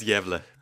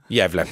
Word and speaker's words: Gävle: I'm delighted to be joined in studio this evening by Christian, newly appointed chief conductor of the, now you can pronounce, I've always said gavel, but I think there's probably a Swedish way of saying gavel symphony orchestra that doesn't sound at all like gavel, Christian. What is it? – It's Gävle. – Gävle. Thank you very much. The Gävle Symphony Orchestra.